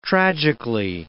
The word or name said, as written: tragically